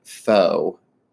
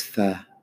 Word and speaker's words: pho: Well, I'm very, very stressed about it because I once called it faux. And someone was like, it's pho.